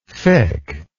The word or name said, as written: fiqh